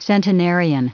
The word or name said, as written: centenarian